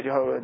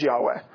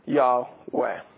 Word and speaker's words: Yahweh: As a general rule, pretty much everybody says, okay, Jehovah, yeah, that's not it. It is Yahweh, or something very close to the pronunciation, Yahweh.